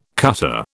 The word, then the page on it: cutter: British English